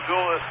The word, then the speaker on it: Euler: Yeah, it's apparently it's pronounced Euler, and that's the way the guy pronounced his own name.